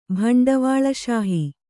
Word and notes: ಭಂಡವಾಳಶಾಹಿ: bhaṇḍavāḷa śahi

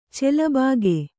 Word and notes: ಚೆಲಬಾಗೆ: Kannada

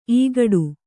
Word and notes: ಇಕ್ಕೋಡು: ikkōḍu

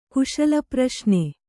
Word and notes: ಕುಶಲಪ್ರಶ್ನೆ: kannada

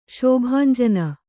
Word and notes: ಶೋಭಾಂಜನ: śobhānjana

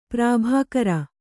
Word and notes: ಪ್ರಾಭಾಕರ: prābhākara

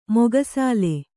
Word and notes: ಮೊಗಸಾಲೆ: moga sāle

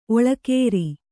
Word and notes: ಓಲೆಕಾರ: ōlekāra

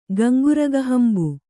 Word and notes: ಗಂಗುರಗಹಂಬು: Kannada

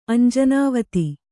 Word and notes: ಅಂಜನಾವತಿ: añjanāvati

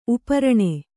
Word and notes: ಉಪರಣೆ: uparaṇe

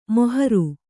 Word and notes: ಮೊಹರು: moharu

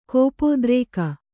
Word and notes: ಕೋಪೋದ್ರೇಕ: kōpōdrēka

